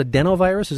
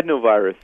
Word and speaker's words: adenovirus: So this is called adenovirus, is that right? – Yeah, adenovirus. – Adenovirus. Yep. Okay, I got the accent on the wrong syllable.